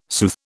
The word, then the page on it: soothe: British English